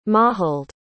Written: Maughold

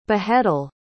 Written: beheadal

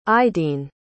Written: aideen

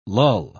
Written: lull

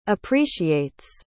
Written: appreciates

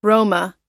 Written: roma

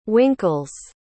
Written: winkles

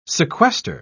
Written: sequester

